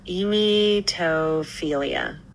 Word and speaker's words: emetophilia: Can you pronounce the word emetophilia? – That one is pronounced emetophilia.